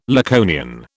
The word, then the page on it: Laconian: British English